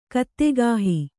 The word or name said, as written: ಕತ್ತೆಗಾಹಿ